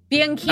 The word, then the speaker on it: Bianchini: Bianchini.